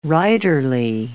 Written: writerly

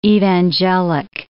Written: evangelic

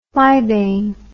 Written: flybane

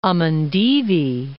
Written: Amindivi Islands

